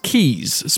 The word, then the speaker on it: K3s: Rancher Labs has announced Keys, spelt K3S, and as they haven't provided a pronunciation guide, I get to decide how it's said out loud.